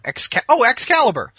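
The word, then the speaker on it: XKALYBR: It's X-K-A-L-Y-B-R. Excalibur. He just spelled it weird.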